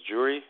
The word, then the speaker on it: Yuri: It's Yuri. I mean, the name is the same, but the spelling can be different. In my case, it's Y. Y-U-R-I. Yuri. So. U. – And then your last name. – U. Yes. Y-U-R-I.